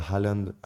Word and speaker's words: Haaland: Et euh, à City, même s'il y a Haaland, à euh, en français, les Argentins prononcent la H, à l'anglais c'est drôle.